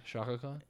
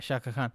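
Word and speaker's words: Shao Kahn: Shaka Khan. – Yeah, Shaka Khan.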